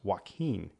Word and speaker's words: Joaquin: And his first name is pronounced Joaquin.